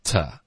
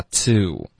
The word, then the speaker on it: to: To 这个字我们就弱读成 ta， OK，不用嘟嘴发 to， OK。